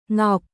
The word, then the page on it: Ngọc: Vietnamese